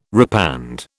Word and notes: repand: British English

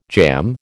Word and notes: jamb: American English

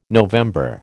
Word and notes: November: American English

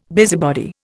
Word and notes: busybody: Indian

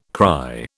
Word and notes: cry: British English

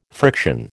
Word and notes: friction: American English

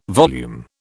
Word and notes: volume: British English

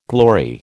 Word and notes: glory: American English

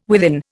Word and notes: within: Indian

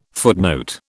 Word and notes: footnote: British English

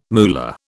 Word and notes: moolah: British English

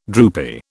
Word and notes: droopy: British English

